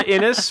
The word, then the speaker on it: Innes: It's just like Guinness the beer, minus the G. – Well, all right. Laura Innes from The Dreamer and Paper Wings Podcast.